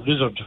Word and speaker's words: Lissard: My Cummins didn't know about Lizard, but he knew about Lizard, which is what we always called it in Galbally. It's spelled L-I-S-S-A-R-D.